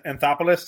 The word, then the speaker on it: Anthopoulos: And Mr. Anthopoulos, that's how you say his name.